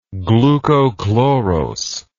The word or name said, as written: glucochlorose